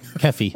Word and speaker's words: Keffe: How do you spell that, Keffy? – K-E-F-F-E. – Okay. – Keefy? – Keffy. – What about Keefy? – That was a Trump tweet, wasn't it?